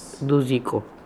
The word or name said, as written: Dooziko